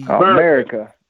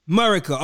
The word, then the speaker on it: America: Now, on the song, there's a song called Marika. I want to actually... – America. – Oh, I'm sorry. How do you pronounce it? I'm sorry. America. Okay. America. My bad. America. – See, you ain't redneck enough, son. You gotta I see. – America. – America. Okay. Got it.